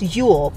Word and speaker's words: Jul: I think it's still pronounced Yule, but it's spelled J-U-L.